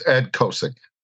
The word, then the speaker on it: Ed Kosiec: It's Ed Kosiec.